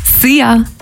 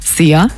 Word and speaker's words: Szia: Szia. Hallo und Tschüss. Könnt ihr euch ganz easy merken. Wird einfach ausgesprochen wie unser Energy Star. Szia.